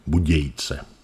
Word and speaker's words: Budějice: To i mezi j a c se nevyslovuje. Budějice.